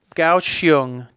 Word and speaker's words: Kaohsiung: Kaohsiung, Taiwan.